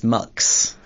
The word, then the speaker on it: Mx: And it's pronounced Mux.